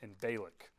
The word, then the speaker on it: Balak: I just learned how to pronounce both those names, by the way. Google's very helpful. Balaam and Balak. Been pronouncing them wrong my whole life.